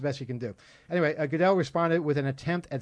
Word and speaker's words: Goodell: Yeah, Tuesday, someone hacked the NFL's Twitter account and announced that Commissioner Roger Goodell, am I pronouncing it right? – Goodell.